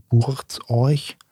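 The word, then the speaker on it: Buurtzorg: Oder man findet mittlerweile auch relativ viel im Internet ähm, wird es euch wird ein bisschen seltsam, also für, deutschsprachige ein bisschen seltsam äh, ausgesprochen buchstabiert. Also ausgeschrieben wird es B Doppel U R T Z O R G, ne? also Burz euch.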